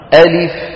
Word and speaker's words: ألف: ألف هكذا تنطق، ألف، انتبهنا بهذه السرعة، ألف. بعض اخواننا بيقولوا ألف لا ألف لا، يخطفوه. ليه؟ سوي يا اخي بين أزمنة الحروف. ألف بوزن فاعل.